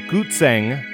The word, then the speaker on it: guzheng: But Gutzeng, it sounds German to me. I assumed it was Germanic. It's actually a Chinese instrument. And of course, part of this was recorded on a Gutzeng. And it's spelled G-U-Z-H-E-N-G.